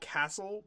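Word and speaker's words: Cassel: I believe it's Cassell. Is that how you say it? – In Buffalo, we call him Matt Castle, okay.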